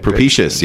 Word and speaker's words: propitious: That's right. You said it right. Propitious. Yeah. Yeah, or propitious. Yeah. – That's a tough one. – Because you want to say propitious. Propitious. So it's propitious.